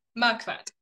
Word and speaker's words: Marquardt: Marquardt.